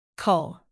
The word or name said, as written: cull